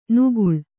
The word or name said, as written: ನಿಗಳ